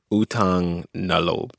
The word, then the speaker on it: Utang na loob: Utang na loob.